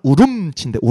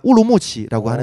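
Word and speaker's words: Ürümqi: 그 위구르 말은 우름치인데 우름치. 네. 우름치. 근데 이제 중국어 발음만 우르무치라고 하는데 우름치.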